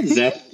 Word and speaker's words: Zeth: Yeah, I mean, we say Zeth like Geth because in our mind, okay, it's basically it's like an ETH client, but lots of people say it Zeth as well.